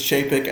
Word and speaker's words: Chapek: The pronunciation is Chapek, and I'm a redneck, and I can't help it.